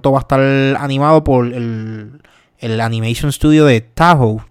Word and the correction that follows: Toho: Tahoe es que se llama, lo dije bien. ¿Sabes cuál es? – Toho. Pues Toho lo, lo va a estar animando, que son los mismos que hacen My Hero Academia.